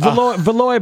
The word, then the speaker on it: Valois: So it's Valoy, but spelled like Valois, right? With an O-I-S? – Yeah, exactly.